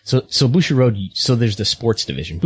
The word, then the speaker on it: Bushiroad: Bushiroad? – That's yeah, okay.